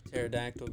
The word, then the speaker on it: pterodactyl: Why can't you hear the pterodactyl going? Because the P is silent.